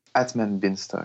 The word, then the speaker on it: Atman Binstock: Atman is how you say his name? From the Oculus team? – Atman Binstock. – Yeah.